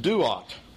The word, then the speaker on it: Duat: The star is also a hieroglyphic symbol, and it stands for a word that's pronounced Duat. Duat is the Egyptian word for netherworld or for the afterlife.